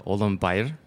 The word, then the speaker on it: Ulambayar: My last name is pronounced in Mongolian as Ulambayar. It has a little bit of inflection to the L and M and R.